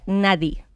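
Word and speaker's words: Nadi (نادي): Club en árabe se pronuncia Nadi.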